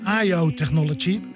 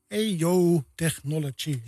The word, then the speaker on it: EO Technology: Volgens mij zei ik IO Technology in mijn allerbeste Engels. Maar het is EO. EO Technology, ja.